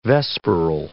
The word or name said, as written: vesperal